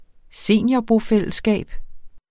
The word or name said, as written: seniorbofællesskab